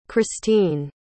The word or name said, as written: christeen